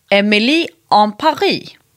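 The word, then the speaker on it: Emily in Paris: Därför att seriens namn ska uttalas som en fransman gör. Nämligen Emily en Paris. Mm. Eller in Paris. Så det ska liksom rimma.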